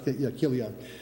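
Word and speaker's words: Chilion: It's pronounced Kilion. The CH actually takes a K there.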